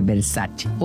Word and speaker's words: Versace: Por ejemplo, Versace, cuando la marca Versace es italiana, entonces tiene que pronunciar Versace. O